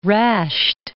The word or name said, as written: Rasht